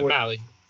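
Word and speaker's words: Mahle: Tyler Malley, I can't say his name. – Yeah, Malley.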